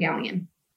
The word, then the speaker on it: Galyean: TikTok, it is my name, Erin Gallien. – And that's spelled G-A-L-Y-E-A-N. – Yes. Gal as in girl. That usually helps.